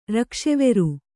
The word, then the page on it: ರಕ್ಕಸವೇರ: kannada